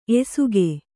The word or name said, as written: ಎಸಕ